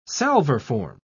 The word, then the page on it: salverform: British English